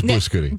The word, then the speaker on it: blue scooty: Now, this is called a blue scooty. I might be saying that incorrectly. – No, you're right. It's blue scooty.